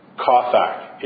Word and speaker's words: chathak: I'm going to spell it C-H-A-T-H-A-K. I'll say it again. C-H-A-T-H-A-K. Kothak.